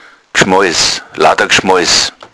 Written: gschmoiss

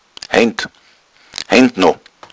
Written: heind

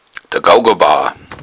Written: gougabauer

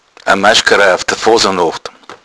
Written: maschgara

